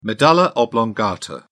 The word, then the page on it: medulla oblongata: British English